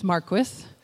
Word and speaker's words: Marquis: So I realize that my last name is challenging to pronounce. It's Marquis. My first name is difficult to spell,